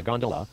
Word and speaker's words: gondola: Known amongst the fraternity as a gondola, with the second syllable emphasized and pronounced long.